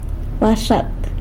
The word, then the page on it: baxat: sanlucasquiavini